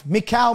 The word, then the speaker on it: Michal: You look at it in um, Michael, David's wife, Michal, Michael, however you want to pr- pronounce it.